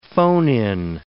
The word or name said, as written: phone-in